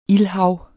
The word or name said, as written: ildhav